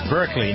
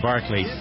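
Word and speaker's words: Berkley: And Elizabeth Berkeley, some pronounce it, is it Berkeley? It's Berkeley, not... – It's Berkeley. – Berkeley, yeah. Some say Barkley.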